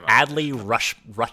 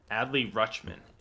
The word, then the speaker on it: Adley Rutschman: Adley Rutschman. – Adley Rutschman.